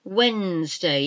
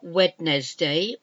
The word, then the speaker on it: Wednesday: Wednesday. It's not Wednesday, although that's how it's spelled. It's Wednesday. Wednesday.